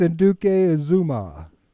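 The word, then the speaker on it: Anudike-Uzomah: How you say it? – Felix Anduke Azuma. – There you go.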